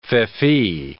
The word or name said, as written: feoffee